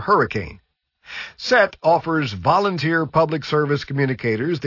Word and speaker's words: SET: SET, usually pronounced as the word SET,